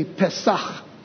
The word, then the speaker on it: Pesach: Say that with me, Pesach. Yeah, don't forget the ch at the end.